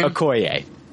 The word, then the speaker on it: Okoye: I, I was it Oikai? Is that how you say her name? Okoye.